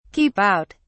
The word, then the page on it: keep out: British English